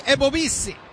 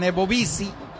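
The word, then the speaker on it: Ebobisse: Está ingresando entonces Jeremy Ebobisi. Dicen Ebobisi. Se escribe Ebobise. Y yo no sé cómo dice.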